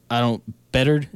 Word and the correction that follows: Beathard: is that how you say his name? The their backup. Beathard. CJ Beathard. Beathard.